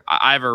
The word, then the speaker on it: Ivor: What's Ivor, not Ivar. Ivor rhymes with Diver.